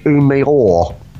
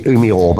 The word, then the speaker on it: Umeå: Now, I believe it's pronounced Umeå. Umeå in Sweden. U-M-E-A with a little circle around it, and it's a bit like Rottenson. Rottenson. So I think it's Umeå, but I'm not sure.